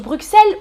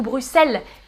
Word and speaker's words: Bruxelles: Ah, Rolo on prononce Bruxelles ou Bruxelles Les deux. En France, on dit en général Bruxelles, mais on peut dire aussi Bruxelles. Et euh, je crois qu'en Belgique, les gens disent Bruxelles.